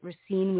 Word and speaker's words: Racine: So, just thinking back to, if I'm pronouncing it right, Racine, Wisconsin.